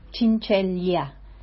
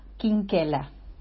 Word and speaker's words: Chinchella: En Italia la CH se pronuncia como Q y la L-E o la doble E. Entonces su apellido se pronuncia Quinquela.